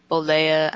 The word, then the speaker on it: Bollea: Linda Bollea, I hope I'm saying that right, Miss Elizabeth, and Woman, by disqualification.